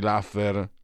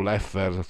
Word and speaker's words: Laffer: diciamo la stella polare sarebbe la curva di Laffer o Leffer forse la pronuncia più corretta cioè,